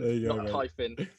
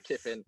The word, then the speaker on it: Kyffin: It's Reece Kyffin, Kyffin. Kyffin.